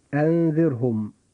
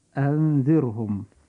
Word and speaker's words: أنذرهم: كلمة أنذرهم، نجد أن هذه الكلمة فيها حرف الراء ساكن، وجاء قبل حرف الراء هذا كسر أصلي وهو الكسر الموجود في حرف الذال، فنجد أن حرف الراء ننطقه في هذه الكلمة مرققا كما تقول القاعدة.